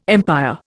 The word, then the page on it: empire: Indian